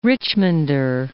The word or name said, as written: Richmonder